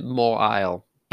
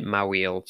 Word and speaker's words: Mawile: So this one is Pokemon known as, now I pronounce it Mawile, but I know some people call it Mawile, so whatever your preference, none of my business.